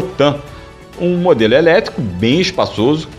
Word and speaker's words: Tang: Uma curiosidade sobre o TAN é que se escreve TANG, mas aqui a BID vai abolir a letra G. A BID garante que não é por causa daquele suco em pó que a gente bebia, famoso lá nos anos 80 e 90. Mas sim porque na pronúncia em mandarim não se fala o G mesmo, é TAN.